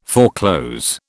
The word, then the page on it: foreclose: British English